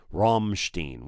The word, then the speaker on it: Rammstein: Somebody said it's R-U-M-M-Steen, Rammstein, which I think is right. But another guy said it's Ramstein. So I think it's Rammstein.